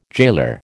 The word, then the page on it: jailer: American English